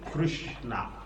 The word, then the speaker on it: Krishna: It is pronounced Sri Krishna. Krishna. Not Krishna. Ramo. That is Bengali pronunciation. Although Prabhupada is Bengali, but he doesn't pronounce like that. It's their tongue. The pronunciation is Krishna. You hear Prabhupada use the word, you pronounce Krishna. Not Krishna.